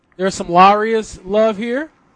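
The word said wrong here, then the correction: Iaria's: Is it I or L? – Iarias. Yeah. Right off college.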